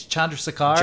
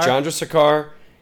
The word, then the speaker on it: Chandrasekhar: It was I'm blanking on the name, but the Jay, I believe it's pronounced, I think it's Chandrasekhar. – Chandrasekhar.